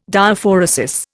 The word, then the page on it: Diaphoresis: Indian